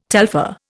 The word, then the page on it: telpher: Indian